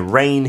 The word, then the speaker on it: reign: Yeah, and a rain here, we're not talking about 비 오는 영국, no. R-E-I-G-N, 지는 묵음이죠.